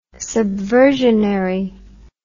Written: subversionary

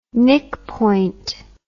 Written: nickpoint

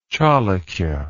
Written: chalukya

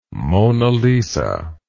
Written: Mona Lisa

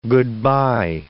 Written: good-bye

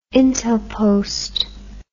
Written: intelpost

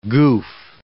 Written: goof

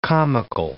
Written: comical

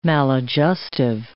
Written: maladjustive

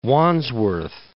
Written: Wandsworth

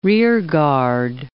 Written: rearguard

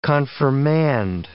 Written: confirmand

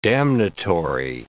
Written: damnatory

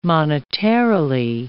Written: monetarily

